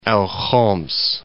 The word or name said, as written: Al Khums